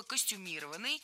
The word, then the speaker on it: костюмированный: Много раз прозвучало слово «костюмированный» с ударением на третьем слоге. И сейчас это верное ударение. Но раньше нужно было говорить «костюмированный». Однако на данный момент это слово с таким ударением уже помечают как устаревшее. И правильно делают. Современная норма – только «костюмированный».